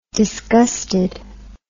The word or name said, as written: disgustedness